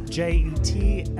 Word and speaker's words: Jetski: So it's Devin, and then Jetski, Jetski like the watercraft.